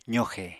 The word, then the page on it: zoxe: apatani